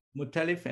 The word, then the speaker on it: Muttalif: It is at, tell me how to pronounce your last name, Naseer. – Muttalif, M-U-T-T-A. – Muttalif. So it's M-U-T-T-A-L-I-F, Naseer.